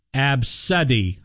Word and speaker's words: ABCDE: All right, so that's the name, ABCDE, pronounced Absuddy.